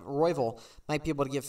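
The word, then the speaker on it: Royval: Royville, yep.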